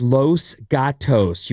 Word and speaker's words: Los Gatos: It's Los Gatos. You keep saying Los.